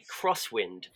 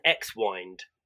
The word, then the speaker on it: X-Wind: Jeffrey Wright's watch is by a company called Hamilton, and it's called a Crosswind. And I for a lot and the way that they spell it is with an X, and it's WIND. So for a longest time, I called it an X-wind.